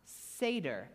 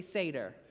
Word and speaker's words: Seder: Ah, Seder. Everybody say Seder. Or Seder in the current pronunciation.